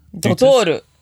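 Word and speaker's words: Doutor: Tell me, could you describe for me the brand images of Starbucks versus, I can't pronounce it, Dooters? Dottore? – Dottore.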